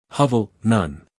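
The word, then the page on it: hovel: uk ˈhɒvl; en-us ˈhʌvl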